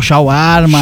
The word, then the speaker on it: shawarma: Shawarma, é isso? – Shawarma.